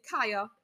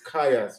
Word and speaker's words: Chayah: Ladies and gentlemen, please welcome Hyah. – Thank you. – Did I say that right? – It's Chayah. – I always used to say that wrong as well before. It's Chayah, sorry.